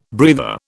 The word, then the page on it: breather: British English